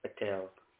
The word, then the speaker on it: Patel: Patel. – Could you spell that, please? – P for Peter, A-T-E-L. Patel. – Patel. P-E-L. P-A-P-E-L. Is that right? – P-A-T.